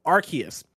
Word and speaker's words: Arceus: Ignacio Rojas writes in to say it's pronounced Arceus.